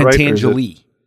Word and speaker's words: Pentangeli: No, okay, I can never pronounce his name right. Pentangeli, is that Pentangeli. – Right? Pentangeli. Pentangeli. – Yes.